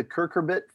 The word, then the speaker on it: cucurbit: These varieties make up the cucurbit family.